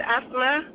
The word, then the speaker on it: Asma: Name is Asma. – How do you spell that? – A-S-M-A. – Asma? – F-L-A.